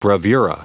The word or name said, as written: bravura